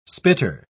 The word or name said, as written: spitter